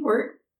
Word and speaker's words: Wirt: Wirt? – Yeah, W-I-R-T.